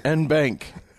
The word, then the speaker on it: en banc: Ong, and you're not pronouncing the G. – N bank. – N bank. Yeah, that works.